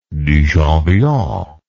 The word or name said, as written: duchamp villon